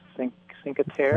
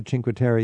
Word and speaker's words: Cinque Terre: What would be your opinion about going more on the coast to, I think it's pronounced Cinque Terre? – I was thinking about the Cinque Terre.